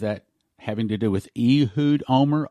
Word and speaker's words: Olmert: And in Israel, they pronounce it, now we may pronounce it Olmert, with a T on the end, the T is silent. Israelis pronounce his name Ehud Omer, Ehud Omer.